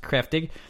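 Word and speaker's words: Kräftig: It's spelled Kraftig. It's pronounced Kreftig.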